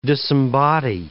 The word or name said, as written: disembody